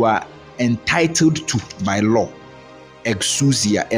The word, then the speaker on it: exousia: So the word all authority, the word authority in the Greek is exousia. Let me spell it for you. E-X-O-U-S-I-A. E-X-O-U-S-I-A.